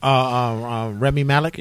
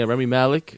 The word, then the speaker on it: Rami Malek: Rami Malek. – Yeah, Rami Malek.